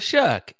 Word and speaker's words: Shuck: It rhymes with one of the worst curse words you could say. – Oh. Shuh. – There you go. You got it, Tyler Shuck.